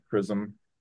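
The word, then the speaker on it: XRISM: We renamed it to CHRISM. So if you learn nothing else from this talk, that's how you pronounce it.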